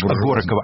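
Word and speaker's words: Horáková: А суд над Миланой Гораковой по обвинению в... – Горокова, она Горокова.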